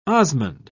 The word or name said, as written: osmund